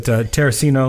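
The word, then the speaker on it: Tarracino: Well, this song is about Tony, is it Terracino, am I pronouncing that right? – Yep.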